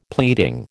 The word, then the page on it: pleading: American English